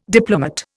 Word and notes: diplomate: Indian

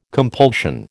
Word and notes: compulsion: American English

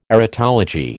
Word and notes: erotology: American English

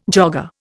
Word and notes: jogger: Indian

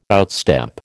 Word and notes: outstep: American English

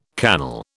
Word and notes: cannel: British English

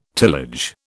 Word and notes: tillage: British English

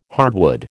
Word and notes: hardwood: American English

